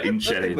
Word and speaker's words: Inisherin: The Banshees of Inisherin? Am I pronouncing that right? – Yeah, Inisherin.